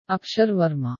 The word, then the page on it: Akshar Varma: Malayalam; India